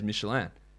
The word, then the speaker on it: Michelin: You got to say it like the American way. Like Michelin is not an American company; it's a French company. That is why I said it as Michelin because it is not, and it's and it, multiple people said that.